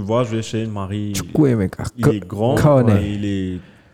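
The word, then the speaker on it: Chukwuemeka: Juste dis-moi bien la prononciation. Prononcez le nom. Tu as le truc là Ok. Il des titulaire, je crois. Chukwemeka. Chukwemeka. Moi Tueku, moi. – Chukwemeka.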